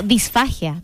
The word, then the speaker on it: disfagia: Día Mundial de la Disfagia. No, disfagia. Lo dije mal, ¿cierto? Disfagia.